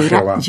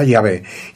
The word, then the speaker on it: Yahvé: Y claro, pusieron las vocales de Adonai y pensaron sería Jehová o algo así, pensaban ellos. – Ah. – Les pareció, pero se demostró que no era así, no era Jehová. – Era Yahvé.